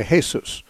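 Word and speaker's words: Jesús: If you're a Spanish-speaking person, you'd say Jesus, which is spelled the same as Jesus, but pronounced differently, because in Spanish they pronounce the J as an H.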